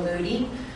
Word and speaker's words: Moodie: Paula Moody. I live at 1217 Duryea Drive. My last name is spelled M-O-O-D-I-E.